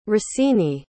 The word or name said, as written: rossini